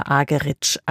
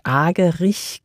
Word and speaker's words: Argerich: Zum Orchester mit Charles Dutoit kommt jetzt Martha Argerich als Solistin dazu. Hierzulande wird sie oft eingedeutscht Argerich genannt, aber sie selbst spricht sich Argerich.